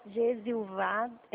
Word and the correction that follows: Gesivaldo: como é que fala o seu nome? Me ensina, por favor. – Gesivaldo? – Ah, Gesivaldo. Elisângela, tudo bem? – Tudo bem?